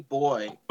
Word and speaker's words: buoy: How do you pronounce it? Say it again. Ian and I fight over this. – Hoppy boy. – Boy! – Like... – Boy. – I'm saying boy, like a small boy or something.